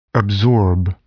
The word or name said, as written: absorb